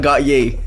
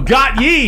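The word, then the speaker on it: Gotye: Not got ye. – No, got ye. Got, got ye.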